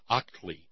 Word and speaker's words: Octli: It was called by them Octli, O-C-T-L-I. It is still consumed today in Mexico and is called in Spanish pulque, P-U-L-Q-U-E.